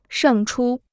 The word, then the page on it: 胜出: shèng chū